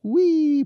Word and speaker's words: Hwii: and I love how he even says it's pronounced Wee, but it's spelled H W I I.